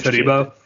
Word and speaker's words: Todibo: Todibo.